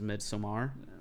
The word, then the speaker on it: Midsommar: A lot of people are calling it Midsommar, but yeah. – Technical pronunciation is Midsommar, but you can call it whatever you want.